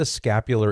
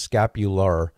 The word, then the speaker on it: scapular: Sometimes I hear people pronounce it scapula. So unless you're from New York City, you don't want to say scapula. It's scapular. It has an R at the end.